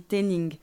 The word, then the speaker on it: Tennin: mais c'est mon deuxième prénom qui s'écrit normalement avec un G et c'est un prénom Paul, c'est mon père qui me l'a donné, donc on dit Ténin.